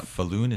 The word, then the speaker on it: Falun: Falun.